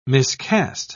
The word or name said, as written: miscast